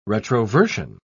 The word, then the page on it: retroversion: British English